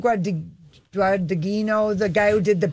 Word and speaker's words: Guadagnino: I just know that's it's Guadagnino.